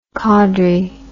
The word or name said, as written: Cawdrey